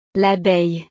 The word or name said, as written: l'abeille